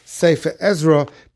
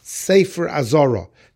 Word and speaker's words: Azora: And others say that it's the sefer that was in the Azoros, not to be pronounced Sefer Ezra, but rather to be pronounced Sefer Azora.